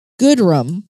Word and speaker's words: Goodrum: He says that as he was hanging out in the room with Katie, his friend, Lucas Goodrum, it's spelled G-O-O-D-R-U-M, like good rum.